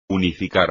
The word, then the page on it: unificar: espagnol